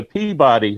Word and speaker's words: Peabody: Since you're north of Boston, how do you pronounce P-E-A-B-O-D-Y? – Peabody.